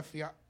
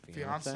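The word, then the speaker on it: fiancé: Fiance? – Fiance? – Yeah. – I mean, it rhymes with Beyonce.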